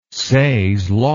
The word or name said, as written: says law